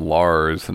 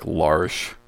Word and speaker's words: Lars: Well, they call him Lars, and it's actually like Larsh. – Larsh. – Yeah. It's spelt Lars, but it's Norwegian, so the way you pronounce it is different.